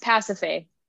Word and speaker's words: Pasiphae: Anyways, it's Pasiphae. – Pasiphae, is that how you say it? Mm-hmm.